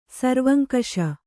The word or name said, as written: ಸರ್ವಂಕಷ